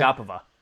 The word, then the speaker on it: Agapova: Can you pronounce that for me again? – Agapova. – Agapova.